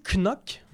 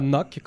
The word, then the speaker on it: Knock: Oui, en fait, il faut prononcer Knock. Je sais que... – Parce que c'est pas anglophone du tout. Oui, c'est spécial. On s'attend à ce que ce soit Knock, comme cogné sur la table.